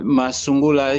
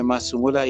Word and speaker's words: Massungula: C'est parce que Massungula, il y a certaines personnes, surtout au lieu du travail, ne parvenaient pas à prononcer mon nom complètement comme Massungula, il disait alors Massou.